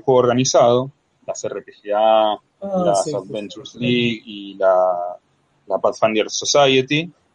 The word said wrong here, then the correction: Pathfinder Society: Lo pronuncié muy feo, muy feo, así que lo voy a pronunciar de vuelta. La Pathfinder Society. Gracias. Sí, ese es el inglés moronense.